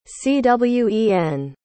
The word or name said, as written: cwen